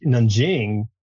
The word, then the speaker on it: Nanjing: You know, meanwhile, like Nanjing, I'm pronouncing that correctly. – Yeah.